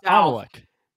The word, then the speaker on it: Amalek: I don't even know if I'm saying this right. That's I'm a bad pastor, but Amalek. That's they head south. – Amalek. – There you go.